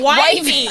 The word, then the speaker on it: Wifey: And she's like, when people read it, they're going to say Wi-Fi. I was like, but it's pronounced Wifey.